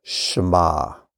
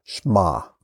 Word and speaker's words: Shema: So it's Sh-M-Ah, Shema. And I pronounce it with two syllables, but you may hear some pronounce it as one, Shema, which is kind of hard to do, is one syllable.